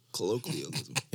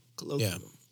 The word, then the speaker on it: colloquialism: Colloquialism. Yeah. I said it right. – Yep. – That's it. Colloquial, because it's like colloquial. Colloquialism.